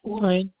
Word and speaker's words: Warren: And that's spelled S-A-R-A-H? – Yeah, that's good. – Okay. And what's the last name? – Warren. – You said Warren? – Yes. – And that's spelled W-A-R-R-E-N? – Yes, that is correct.